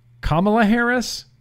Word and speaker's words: Kamala Harris: Now, if we were going to talk about, I'm going to say her name wrong, Kamala Harris.